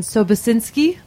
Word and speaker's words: Sobocinski: We've got Brian Civitello and Jason Sobosinski.